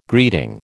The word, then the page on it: greeting: American English